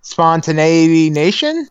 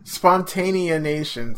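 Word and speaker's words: Spontaneanation: Spontaneity Nation? – No. – What is it? – Spontanea Nation. Spontanea. – Spontanea Nation. It doesn't look like it's spelled that. Why wouldn't you do, like, T-I-A-N?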